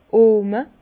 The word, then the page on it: d'r Ohme: Bas-Rhin; Herrlisheim